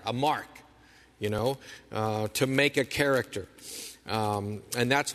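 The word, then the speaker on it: character (χαρακτήρ): If you know your Greek letters from college, if you remember them, the way to pronounce that is character. Character.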